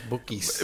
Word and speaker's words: Bukis: I always thought you were just pronouncing Bucky's in a B-U-K-I-S. – Marco Antonio Solis. – Yep. Bookies.